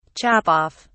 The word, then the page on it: chop off: British English